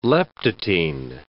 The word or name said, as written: leptotene